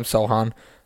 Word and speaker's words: Sochan: They still have Jakob Poeltl, Jeremy Sochan, right? That's how you say his name, Sochan.